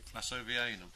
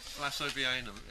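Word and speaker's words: wlassovianum: It's the Vlasovianum. – That's the one. – Vlasovianum. It starts WL. A-double-S, so it's yes, it's a bit of a mouthful, but a lovely plant.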